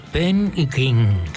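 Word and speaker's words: Ben E. King: Hoy habría estado de cumpleaños Benny King.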